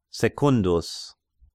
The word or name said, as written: Secundus